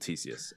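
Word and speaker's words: Ctesias: The name is spelled like C-T-E-S-I-U-S, which is kind of weird, but that's a different thing. He's called Theseus, and he kind of saw and described the unicorn in the historical record.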